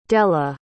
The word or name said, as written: della